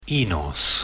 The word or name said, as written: Enos